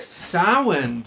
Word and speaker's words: Samhain: Samhain, Patrick.